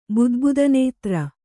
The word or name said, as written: ಬುದ್ಬುದನೇತ್ರ